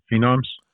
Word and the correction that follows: phoneme: Phonemes. Phonemes. Although okay, I am a phenom, it's a phoneme.